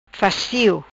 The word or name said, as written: fastio